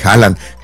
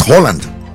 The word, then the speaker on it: Haaland: Que me dicen que no se pronuncia Haaland, es Holland. – Holland. – Nosotros le decimos Haaland porque viene a. Sí. Pero es Holland.